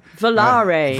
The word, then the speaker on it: Volare: Is that how you pronounce it? Volare, Volare? – Volare. – Volare. – Volare, okay.